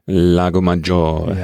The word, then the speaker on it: Lago Maggiore: Und du steckst deine Füße noch in den, wie heißt es jetzt ausgesprochen, in den Lago Maggiore. – Ja, genau.